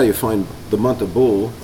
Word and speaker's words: Bul: And that'll be Bul, that's spelled B-O-O-L. In English they spell it B-U-L, which is a little different.